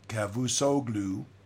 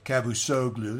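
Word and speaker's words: Çavuşoğlu: The United States, this is a quote, by the way, from Mevlut Cavusoglu, I guess that's how you say it. I don't speak Turkish, but Cavusoglu, there it is.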